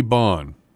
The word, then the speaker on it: Bohn: Our quote this morning comes from H.G. Bohn, not pronounced bone, but spelled the same way as a certain somebody that many of us know.